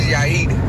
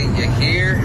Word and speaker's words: Yair: My name is Yair, but white people always say Yair. Like they always say something different. It's not that hard, but it's not that hard.